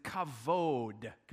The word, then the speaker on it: kavod: And the Hebrew word is kavod. Could you say that to your neighbor? Kavod. You got to say it like that.